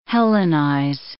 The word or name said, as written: hellenize